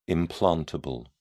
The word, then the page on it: implantable: British English